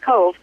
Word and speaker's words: Cobh: this week I'm going to take you across the sea over to Ireland, but I want to take you to Cove, Ireland, which is spelled C-O-B-H, and it's pronounced Cove.